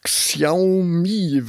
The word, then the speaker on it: Xiaomi: Xiaomi, wenn ich das richtig ausspreche.